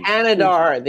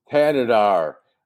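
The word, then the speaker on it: Thanedar: I mean Thanadar. The H is silent. – Thanadar.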